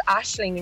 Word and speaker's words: Aisling: And most people pronounce it thinking there's a H in it. So they're like Aisley, or the worst one I've been called is Aisling. – Aisling. – Oh my God. And we're like, no, it's Aisling. And we're like, you've got no H in your name. But it's just the Irish pronunciation of it.